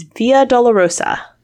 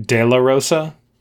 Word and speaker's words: Via Dolorosa: But you have to be careful with the pronunciation. As I heard her say it, it made me realize, like, it would be very, very easy to say something like Della Rosa, because Della mm-hmm.